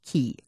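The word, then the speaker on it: Caye: And the little town of San Pedro is on an island called Ambergris, A-M-B-E-R-G-R-I-S, Ambergris Key or K, depending on how you say it.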